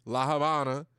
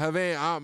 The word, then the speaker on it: Habana: V's is pronounced with a B, La Habana. Not Havana, my American ass Havana, La Habana.